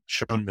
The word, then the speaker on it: Sean: So there's another charity called Winter for Kids based out of New Jersey, Shane Miller is how you say it. It's written Sean.